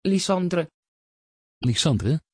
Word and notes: Lysandre: Dutch

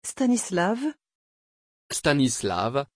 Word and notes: Stanislaw: French

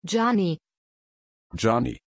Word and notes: giani: German

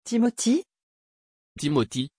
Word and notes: timothy: French

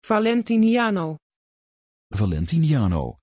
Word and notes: valentiniano: Dutch

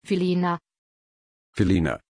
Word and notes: philina: German

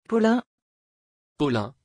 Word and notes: paulin: French

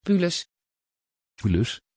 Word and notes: paulus: Dutch

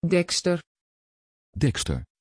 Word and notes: dexter: Dutch

